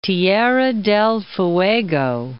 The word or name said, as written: Tierra del Fuego